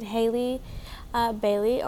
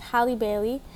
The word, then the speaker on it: Halle Bailey: Basically, Disney is making a reboot of The Little Mermaid, of course, and they have casted Hailey Bailey, or I believe it's pronounced Halle Bailey, and she is a beautiful Black actress.